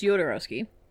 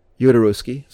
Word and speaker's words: Jodorowsky: And so then, because Dan O'Bannon was hired by Alexander Jodorowsky from that experience to work on Dune. – I believe it's Jodorowsky. – Jodorowsky, sorry.